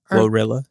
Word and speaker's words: GloRilla: Gorilla. That's how you say her name, right? – Glorilla.